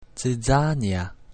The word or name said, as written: zizania